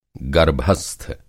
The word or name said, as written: गर्भस्थ